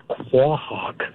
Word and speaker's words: quahog: Yes, it is pronounced quahog. You say the Q and the U and the A. It's quahog.